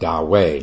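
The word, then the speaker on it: Dahui: By the way, Da Wei is spelled D-A-H-U-I. D-A-H-U-I. And in China, it's pronounced Da Wei, not Da Wei. Da Wei. I learned that after about 20 years of pronouncing it wrong.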